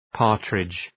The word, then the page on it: partridge: ‘pɑ:rtrıdʒ